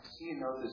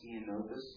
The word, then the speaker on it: Ceanothus: Ceanothus is the genus, and that's spelled C-E-A-N-O-T-H-U-S, Ceanothus.